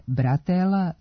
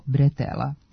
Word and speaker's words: bretela: Međutim, naremenica kao deo grudnjaka, potkošulje, kombinezona, letnje majice ili haljine, remen ili kajš za nošenje tereta nije bratela, nego bretela. Potiče od francuske reči bretel, koja u izgovoru ima nemo e, a ono se u naš jezik prenosi kao e.